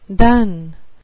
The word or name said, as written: dun